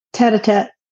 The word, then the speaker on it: tête-à-tête: Tête-à-tête.